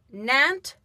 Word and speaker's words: Nantes: Now, you have also spent time in Nantes, France.